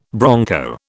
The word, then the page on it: bronco: British English